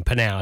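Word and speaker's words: PNAU: Reliably informed by the band, that's how you have to pronounce that that name, Panal. It's got to have the rising inflection of the Panal. It's written into it.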